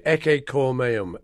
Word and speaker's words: Ecce Cor Meum: We call it Ecce Cor Meum, or Behold My Heart.